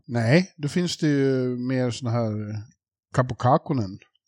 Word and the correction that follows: Kähkönen: Ja, Kähkönen skulle ja. Jarko vilja att, eller det där var, jag slaktar säkert i uttalet också.